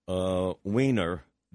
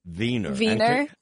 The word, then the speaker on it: Weiner: In Vienna or Berlin, they pronounce Weiner, Wiener. – Wiener.